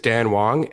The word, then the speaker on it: Dan Wong: It even happens in Canada even more so, because my name is Dan Wong and they pronounce it Steve Chang.